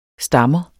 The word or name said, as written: stammer